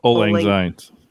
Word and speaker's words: Auld Lang Syne: Auld Lang Syne.